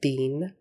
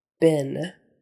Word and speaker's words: been: This is a tricky one because it's spelled E-E and you would think you're supposed to say it bean, but no. In English, especially Western states, United States, bin, bin, as if it were a short I, bin, B-I-N, but it's spelled B-E-E-N.